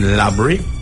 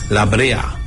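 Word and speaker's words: La Brea: It is located in Labrie in southwest Trinidad, or as they would say outside of Trinidad, Labrea. That's the real pronunciation, mm-hmm. but we say Labrie.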